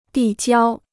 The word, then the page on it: 递交: dì jiāo